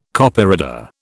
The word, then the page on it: Copyreader: British English